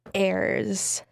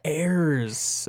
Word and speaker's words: Heirs: It's Suburban Wonderland by Ayers. – Ayers. – Ayers. – How do you spell that? – That would be H-E-I-R-S. – Oh, Ayers.